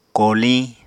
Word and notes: koliñ: Apatani

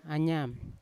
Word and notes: anyan: Yawa; Unat

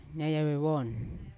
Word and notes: nayave von: Yawa

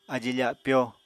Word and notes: ají-fapyó: apatani

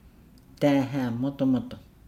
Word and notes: daahaa modomodo: Nukuoro

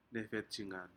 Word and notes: nefetijgan: Aneityum

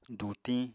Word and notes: dutiñ: apatani

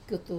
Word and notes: kutu: wamesa

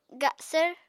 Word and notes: gasvr: Apatani

